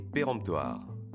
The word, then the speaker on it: péremptoire: Aujourd'hui, notre mot du jour est péremptoire. Attention, le son en de pérent » s'écrit E-M.